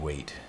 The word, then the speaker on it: Waitt: Steve Wait. It's pretty easy to find because my name, W-A-I-T-T, is spelled a little differently.